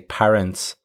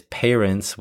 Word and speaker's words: parents: Okay, the first thing to comment on here is the pronunciation of the word parent quite a few of my students are from Spain and Italy, and it's quite common to hear them say parents with pa. The correct way to say it, however, is parents with pay, the same way you would say the verb to pay. So be careful with that one, parents.